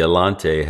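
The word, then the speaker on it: Allanté: And I think I'm mispronouncing that because the Elante has the accent over the E. So it's Elante. You have to put the emphasis on the final vowel there.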